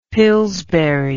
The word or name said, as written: pillsbury